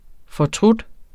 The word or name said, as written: fortryde